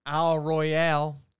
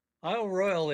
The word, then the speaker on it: Royale: I think I mispronounced it, Isle Royale. You said it has an E on the end, so what's the deal? – It has the E, but it's only pronounced Royal.